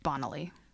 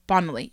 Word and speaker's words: Bonaly: I think it's pronounced Bonaly. – Bonaly, you're right.